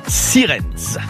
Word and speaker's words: Sierentz: Sierentz. Je crois que c'est un Z au début, mais c'est comme ça que ça se prononce en allemand, je pense. Sirens, dans le Haut-Rhin, foncez et donnez le mot de passe et la valise est à vous.